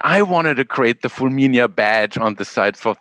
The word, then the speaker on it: Fulminea: And I had to learn to pronounce it because I always pronounced it Fulminea. And I said, no. Alec, you said authentic Italian, right? Like it's Fulminea.